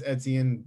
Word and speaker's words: Etienne: Travis Etienne, both played at Clemson.